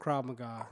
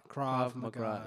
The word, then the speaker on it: Krav Maga: Doing some like Krav McGrath type stuff. – Yeah, as well. Sort of Krav come. – What's the actual pronunciation there? – I think it's called Krav Maga. – Krav Maga.